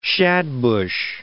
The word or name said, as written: shadbush